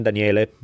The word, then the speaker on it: Daniele: You need to put just my first name, Daniele.